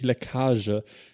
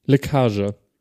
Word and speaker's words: Leckage: Es heißt tatsächlich Leckage. Es wird Lautschrift L-E-K-A-A-Z-H-E. Warte, ich lass es mir mal kurz aussprechen. Leckage.